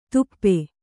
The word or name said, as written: ದುಪ್ಪೆ